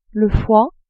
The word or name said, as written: foie